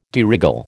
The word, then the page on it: de règle: American English